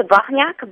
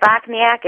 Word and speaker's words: Bochniak: It's a Bochniak, but Bochniak is just fine. – Bochniak. All right. I want to get that accent in there. – It's Polish.